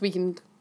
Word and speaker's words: Weeknd: It's pronounced Weeknd.